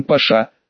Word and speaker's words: Паша: В 25 километрах дальше по железной дороге, там, где дорога пересекала реку с названием Паша, с ударением, как у османских владык, на последнюю букву, по планам нужно было поставить еще один город и небольшой металлургический завод.